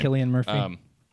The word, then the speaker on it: Cillian: But, you know, Chilich, I don't know how to pronounce his name. – Cillian Murphy.